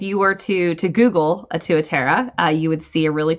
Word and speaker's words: Tuatara: is Tuatera. – Tuatera. Okay. – Tuatera. – Now spell that for our listeners. – Yes. T-U-A-T-A-R-A.